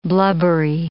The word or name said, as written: blubbery